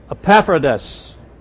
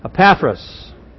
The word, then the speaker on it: Epaphras: Epaphras. I pronounced that wrong. Epaphras.